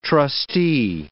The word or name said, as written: trustee